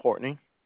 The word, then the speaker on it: Courtney: My name is Courtney.